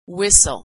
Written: whistle

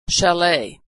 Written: chalet

